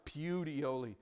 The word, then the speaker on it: Puteoli: And that's what the city of Puteoli, it ought to be pronounced Puteoli, but the city of Puteoli, it was famous for that.